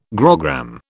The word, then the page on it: grogram: British English